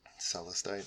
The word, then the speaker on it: celestite: Celestite.